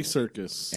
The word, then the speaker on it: Serkis: Circus. S-E-R-K-I-S. Yeah, so that's how you would phonetically spell circus. – Andy Serkis.